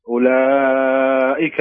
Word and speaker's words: أولئك: اقرأ يلا بدون واو كذا كأن ما في واو أبدا. اقرأ بدون واو. – اقرأ بدون واو. نعم. – أولئك في ضلال مبين. – أحسنت، هذا النطق الصحيح لكلمة أولئك.